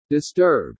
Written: disturb

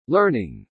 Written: learning

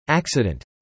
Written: acccident

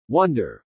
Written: wonder

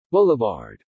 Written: boulevard

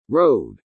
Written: road

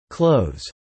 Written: clothes

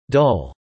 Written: dull

